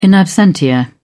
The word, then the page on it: in absentia: British English